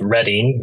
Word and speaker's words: Reading: I am in Pennsylvania, a little town called Reading, which is one of the few American towns which we pronounce with a British pronunciation, because otherwise it looks like Reading.